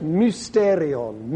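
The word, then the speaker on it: mysterion (μυστήριον): But so this word is pronounced mysterion, mysterion, y, mysterion. Just like the Y it's called, in fact.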